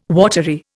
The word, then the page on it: watery: Indian